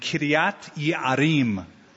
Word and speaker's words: Kiriath Yearim: Now the cities were Gibeon, Chephirah, Be'erot, and Kiriath Yearim, if you want to pronounce it like a Hebrew.